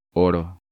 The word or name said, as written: Oro